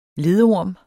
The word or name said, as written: ledorm